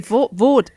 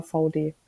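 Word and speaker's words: VAUDE: Ist eine Marke einfach, V-A-U-D. – Very Deutsch. Ach so, nee, Vod. Ach so, ja, aber VD wird die ausgesprochen, ja.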